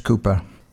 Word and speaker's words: Cowper: It's spelt C-O-W-P-E-R, but pronounced Cooper.